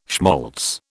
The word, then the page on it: schmaltz: British English